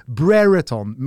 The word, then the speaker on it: Brereton: men man vill säga Ben Brereton med liksom engelskt uttal.